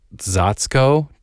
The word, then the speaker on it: czaczko: But like I said, this word comes from kind of old Polish almost proto-Slavic word, if you will, which I looked up the pronunciation and is like Zatsko, C-Z-A-C-Z-K-O.